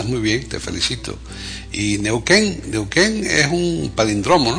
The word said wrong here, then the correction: palíndromo: Sí, palindromo, efectivamente, palindromo, con tilde, okay. Lleva tilde, sí, okay. Exacto. Sí, lo mismo que con los números es capicúa, pues sí. Con las letras es palíndromo, exacto.